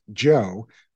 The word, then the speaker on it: Djo: And right now he's focused on his own band, which is pronounced Joe, but it's spelled DJO.